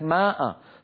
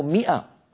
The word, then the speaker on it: مئة: كم وكم نسمع من المثقفين بلك ماء، ثلاثمائة وخمسمائة، أيها الإخوة لا يوجد شيء في اللغة اسمه ماء، يوجد شيء اسمه مئة، مئة، وهذه الألف تكتب ولا تلفظ.